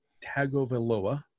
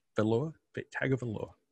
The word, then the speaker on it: Tagovailoa: I will start by saying that I can, in fact, pronounce his name, Taulia Tagovailoa. Tagovailoa. – Tagovailoa. Yeah, just say it quickly.